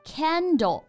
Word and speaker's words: Kindle: 不过有一点确实值得说一说，很多同学刚开始呢读这个词儿读不准，会读成。 Candle 啊，正确的发音应该是 candle。